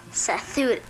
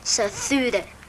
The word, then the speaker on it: Sathura: Satura. – Sathura. And then in the movie they say it like this. Sathura.